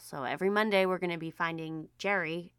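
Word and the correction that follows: Gerry: but it sounds like, oh no, guys, it's pronounced like Gary, not Jerry. Now, I am someone with a unique spelling name, so I try to be sensitive to that. It's Gary.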